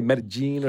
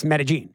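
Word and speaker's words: Medellín: Do they want you to try to say Medellin or do they want you to just say it? – I don't think they really care, but that's not how you say it. It's Medellin.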